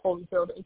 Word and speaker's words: Holifield: And then my last name is Holyfield, HOLIFIELD.